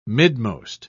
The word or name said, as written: midmost